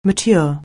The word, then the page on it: mature: anglais